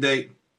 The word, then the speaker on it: Dayton: Tell us about the experience of playing in Dayton.